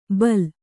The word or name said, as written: ಬೈಲ